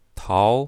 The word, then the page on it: tao2: mandarin